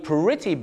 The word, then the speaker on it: pretty: the traffic is too bad หรือว่า the traffic is really bad ลำดับต่อไปเรื่อยๆครับก็คือ the traffic is quite bad ครับคำว่าค่อนข้างอีกอันนึงก็คือคำว่า pretty นั่นเองครับ the traffic is pretty bad ต้องออกเสียงสระอี pretty bad นั่น